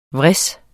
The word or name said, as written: vris